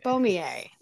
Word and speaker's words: Boismier: but your last name is spelled Boismier. So most people wouldn't look at that and think Beaumier.